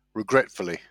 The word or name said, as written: regretfully